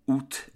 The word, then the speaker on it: août: So for August, AOUT, generally speaking, the French do not say OU and they say OUT. So that final T is pronounced. The word origin of OUT in French is the same as English.